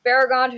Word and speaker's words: Beregond: And then finally, we also meet Beregond. Um, and is that how we say it? Is that how we say his name? – That's how I've always said it. – Beregond.